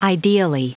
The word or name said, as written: ideally